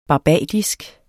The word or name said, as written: barbadisk